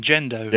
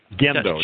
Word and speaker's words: gendo: 250-18, gendo. – Yeah, gendo. – G-E-N-D-O. – Right, and it's a hard G. It's gendo.